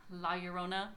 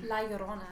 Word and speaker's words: La Llorona: So, recently, I saw the movie La Llorona. I know I don't say it right. – La Llorona.